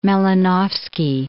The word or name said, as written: Malinovsky